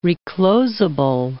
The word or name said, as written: reclosable